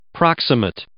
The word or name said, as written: proximate